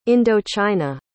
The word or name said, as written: indochina